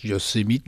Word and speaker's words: Yosemite: Il le prononce Yosemite.